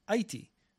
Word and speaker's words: Aichi: It is going to be shifted to the Hepburn rules, which would spell it as A-I-C-H-I, Aichi, which is more phonetically similar to what the Japanese say.